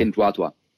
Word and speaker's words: Pinduoduo: and it's called PDD Holdings or Pinduoduo for its Chinese branch. – That's at least how I have been told it's pronounced. Okay. Pinduoduo.